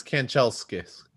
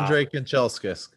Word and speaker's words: Kanchelskis: If I was the butcher, the name would be like Kalshenkis. – That's close enough. It's Kanchelskis. Yeah. Wow. Andre Kanchelskis.